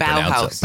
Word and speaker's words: Bauhaus: Wow, I can't Bauhaus.